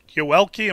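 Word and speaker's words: Yoelqui: You know, we also see the Sox have done a great job with international prospects and noticing that, is it Yoelki, am I pronouncing that right, Cespedes? – Yes.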